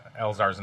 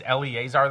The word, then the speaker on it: Elzar: It said Eleazar. I'm like, hi, this is Tide-Eyed Iguana. Is Eleazar there? And he's like, it's Elzar.